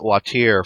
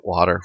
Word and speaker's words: Watir: We actually called it Watir for about, 6 months or so until we realized that it sounded really idiotic, and so we started pronouncing it Water from then on.